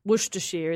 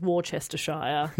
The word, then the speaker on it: Worcestershire: Worcestershire is Worcestershire. – Yeah. Mm. They chop out syllables willy-nilly over there.